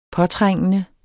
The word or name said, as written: påtrængende